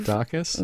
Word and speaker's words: Dacus: This is, how do you say Lucy? Dacus, I believe. – Dacus? – Yeah. – Okay, we'll go with that.